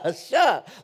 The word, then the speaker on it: Elisha: Eli- Elisha, Elisha, like Eli.